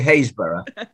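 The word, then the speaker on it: Happisburgh: I'm in Norfolk on the coast near a place called, it's spelt Happisburg, but in English we say Haysborough.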